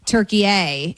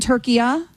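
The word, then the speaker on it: Türkiye: Okay, so it's not what we would call, you and I are making it a hard A, turkey A, and it's turkey-a, he's saying. So it's a soft A.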